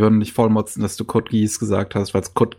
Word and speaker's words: Code Geass: Es wird auch, es wird im japanischen Code Geass ausgesprochen. – Code Geass oh. ist tatsächlich nur so ein deutsches Ding.